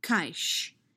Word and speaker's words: Kaysh: K-A-Y-S-H is pronounced Kaish. K as in kill, A-I as in buy, Sh as in shake, all one syllable.